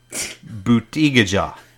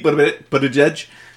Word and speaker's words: Buttigieg: He's Pete Buttigieg. Tell me about this. – Very good pronunciation. – White Venezuelan. – The whitest Venezuelan I've ever seen. – Right. – So it's Pete Buttigieg.